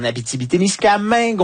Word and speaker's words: Abitibi-Témiscamingue: Et on a également Nicole Fenton, qui est en Abitibi. Témiscamingue, on la joint à Rouen où elle habite. Elle est professeure à l'Université du Québec en Abitibi-Témiscamingue. Bonsoir, Nicole. – Bonsoir, Mathieu. Bien dit. Toutes les syllabes.